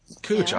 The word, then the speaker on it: Couch: We have a street here in Portland, C-O-U-C-H. Cooch, obviously. That's the way it's pronounced.